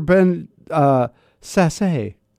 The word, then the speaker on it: Sasse: I think I always say his name wrong. – It's Ben Sass. – Sass. Ben. I don't want to say Sass because that just sounds like, but that is the way it's pronounced.